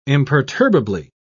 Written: imperturbably

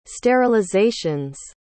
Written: sterilization's

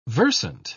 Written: versant

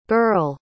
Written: burle

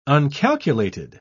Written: uncalculated

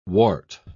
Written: wart

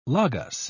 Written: logos

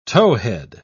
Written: towhead

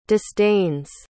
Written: disdains

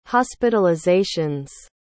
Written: hospitalizations

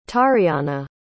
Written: tariana

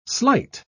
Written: slight

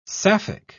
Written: sapphic

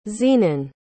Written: zenon

